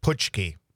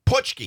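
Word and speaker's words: Pączki: I can never pronounce that word. – It's... – Paczynski? Paczynski? – No. – Puchki. – Puchki.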